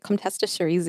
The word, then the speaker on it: Comtesse de Cerise: Yes, we've got the Kongsgaard Syrah, the I'm not going to say this right, Comtesse de Cerise. – That was perfect.